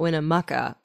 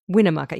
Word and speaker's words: Winnemucca: Winnemucca. Yeah. When you're not from there, you say Winnemucca. And they're like, Yeah. – You put the accent on the win, not the muck.